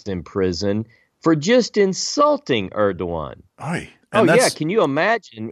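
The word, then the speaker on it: Erdoğan: But Erdogan, remember how you pronounce his name? I got this off Google. – Erdogan, yeah. – Erdogan.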